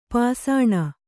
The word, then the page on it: ಪಾಸಾಣ: pāsāṇa